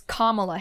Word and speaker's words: Kamala: Apparently it's Kamala Harris, like comma and then la.